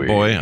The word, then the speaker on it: buoy: Claire is asking me to pronounce buoy. B-U-O-Y. Buoy. – Yeah, buoy. Some people say boy. I say buoy.